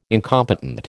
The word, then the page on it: incompetent: American English